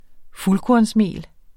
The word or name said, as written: FULDKORNSMELET